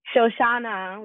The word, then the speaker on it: Shoshana: Shoshana with an A at the end.